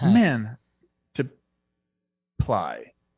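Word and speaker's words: Mantiply: Man. To ply. – Yep. Nailed it.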